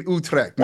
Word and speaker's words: Utrecht: So shouts out to all my people out in Utrecht. Out there in the Netherlands. – Oh, you're big, man. In Utrecht? – Yeah, I am. No, listen, Pete, yeah? I am huge in Utrecht, man.